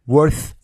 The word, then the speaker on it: worth: is a chance chance 元音念到位啊，如果是英式发音就是 chance chance to work hard at work worth doing，好几个 w o r，那么它的元音呢是发长 a a work work，嘴型扁平一些，不要念成 walk，嘴型太圆了，变成了走路那个单词哈，work hard at work worth doing worth。 th 咬舌是发 s 的发音啊，不是 z 的发音，是 s 的发音。